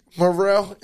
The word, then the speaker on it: Murrell: So it's either TR3 Morrell, MURRELL, or you can spell it like TR apostrophe E.